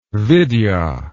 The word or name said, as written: vidya